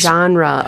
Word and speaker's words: genre: It's, genre. Oh, yes, yes, yes. – It's pronounced with two syllables. Genre or genre. So genre. Genre. You pronounce it. Genre, not like I just did.